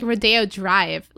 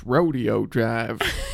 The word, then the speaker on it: Rodeo Drive: They're like gonna like sell it on like Rodeo Drive. Like, it's so good. – Actually, it's pronounced Rodeo Drive.